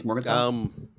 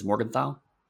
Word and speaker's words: Morgenthau: I think it's Morgenthau. – What's that? – I think it's Morgenthau.